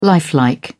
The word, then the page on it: lifelike: British English